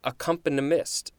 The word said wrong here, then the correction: accompanist: am I saying that right? Accompanist. That just sounds kind of weird. Accompanist, noun circa 1811.